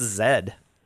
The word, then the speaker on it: zed: Freaking Britain has no respect for the letter Z. – Zed. – They're calling it Zed. I know. It's disrespectful. – It's Zed.